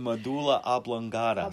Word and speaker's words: medulla oblongata: It's the Sushumna center, you know, it runs from the base of the spine of the brain, with a medulla oblongata. – I can never say that. – Did I say it correctly? – Yes, you did, and I never can. The medulla oblongata.